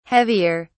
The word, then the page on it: heavier: British English